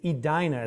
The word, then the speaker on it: Edina: So maybe I'll be back here in lovely, it's Edina is the correct pronunciation, correctly? Okay.